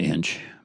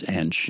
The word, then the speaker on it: Ensch: and my my last name is Ensch. Right. And um, most people pr- pronounce it inch, but it's Anch in German.